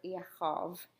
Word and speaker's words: Yaakov: or Yaakov, as it's pronounced in Hebrew.